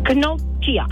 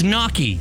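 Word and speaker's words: gnocchi: It's knocky. – What? – It's knocky. The G is silent.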